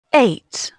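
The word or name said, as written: eight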